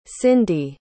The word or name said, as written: cindi